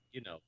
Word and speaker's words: Unova: The two accounts suggest that it could be a new game set in Unova. Am I saying that right? Unova? – Unova, yeah.